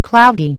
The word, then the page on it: sunny: American English